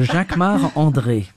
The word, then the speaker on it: Jacquemart-André: Jacques Or no, you say it like André. A Frenchman. – Jacques Mar- oh, it's because I'm French. So Jacques Mar André.